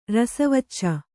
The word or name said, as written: ರಸವಚ್ಚ